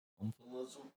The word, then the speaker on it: omphalism: Omphalism? – How do you say that shit right there? – I think you said it right.